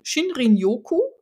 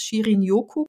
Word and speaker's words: Shinrin-yoku: was wir meistens falsch aussprechen, nämlich so wie es geschrieben wird, Shin, Shinrin-yoku, aber eigentlich wird es Shirin-yoku gesprochen, also man spricht nicht alle Buchstaben, die man sieht.